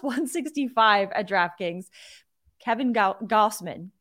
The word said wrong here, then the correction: Gausman: Goss- Gausman. There's three ten ways to pronounce this name. Kevin Gausman.